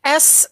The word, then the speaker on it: Osmow: I'm going to give it a try, but Osmau, I think. – Oh, what kind of food? – It is, I want to say Greek or Lebanese. I'm not sure. – I'll let you type it, because from what you pronounced I wouldn't even know how to spell it. So. – It's Osmow.